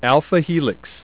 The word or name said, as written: alpha helix